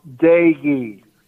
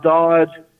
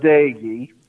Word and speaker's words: Doege: His last name is pronounced Dagey, not Dodge, not Doge, but Dagey.